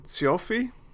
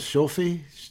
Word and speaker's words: Cioffi: Well, that was the opening to Clute, released in 1971, directed by Alan Pakula, and starring Jane Fonda, Donald Sutherland, Charles, I'm probably going to butcher his last name, Cioffi? – I think it's... That's pretty close. Shofi? – Yeah.